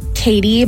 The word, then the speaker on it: Kati: Hi, my name is Kati, spelled like Katie, but it's pronounced Kati.